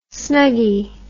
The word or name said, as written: snuggy